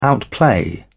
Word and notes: outplay: British English

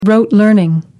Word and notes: rote: American English

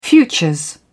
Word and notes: futures: British English

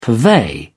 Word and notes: purvey: British English

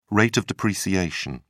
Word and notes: rate of depreciation: British English

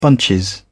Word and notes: bunch: British English

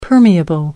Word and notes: permeable: American English

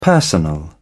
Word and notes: personal: British English

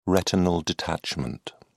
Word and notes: retinal detachment: British English

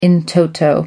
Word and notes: in toto: American English

